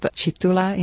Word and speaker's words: Kitula: This is the school in or near the town of Kitula? – Yes, it is a village pronounced Chitula in local Lugandan language.